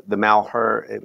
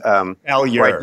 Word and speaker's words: Malheur: about the Malheur, I'm mispronouncing it because I never took French. – Malheur.